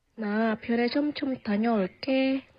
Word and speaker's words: 다녀올게: And the way it's spelt, right? 다녀올게. It's not a 쌍기역 onge, but it is pronounced like a 쌍기역, right? 다녀올게, 다녀올게.